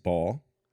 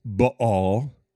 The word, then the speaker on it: Baal: So the word Baal, B-A-A-L, can be pronounced Baal. It could be pronounced Baal. It's B-A with that apostrophe, A-L, Baal. I kind of like that, Baal, or Baal is another way.